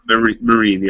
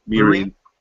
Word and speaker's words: Meereen: I might mispronouncing this Meereen, Meereen, Meereen, yeah, Meereen.